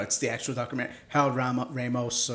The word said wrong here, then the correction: Ramis: or however you say his name. – Harold Ramis.